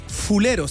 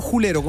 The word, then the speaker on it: fulero: Uno, la palabra es fulero, se escribe, no julero con J. – Claro, es que julero es más flight. – Claro, uno lo pronuncia como julero, pero en realidad lo correcto es fulero.